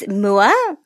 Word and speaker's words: Moua: So it's Moua, Okay.